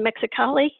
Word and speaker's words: Mexicali: and she crash landed in Mexicali. Am I pronouncing that correctly? – Yeah.